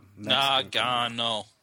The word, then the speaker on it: Nagano: That's just the Nagano. Three syllables.